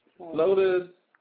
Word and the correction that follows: flauta: some, what I guess they call it a Florida. Some of us call them roll tacos. But. – Oh, flauta. – Whatever. – Flauta, how do you spell it? – F-L-A-U-T-A. – That's flauta.